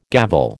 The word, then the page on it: gavel: American English